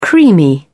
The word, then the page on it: creamy: British English